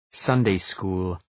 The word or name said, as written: Sunday school